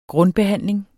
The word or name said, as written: grundbehandling